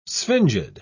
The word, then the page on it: sphingid: British English